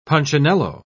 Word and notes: punchinello: British English

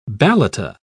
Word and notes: balata: British English

